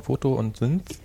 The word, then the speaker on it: Photosynth: Wie wird das ausgesprochen? Photo und Synth.